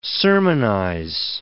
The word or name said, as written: sermonize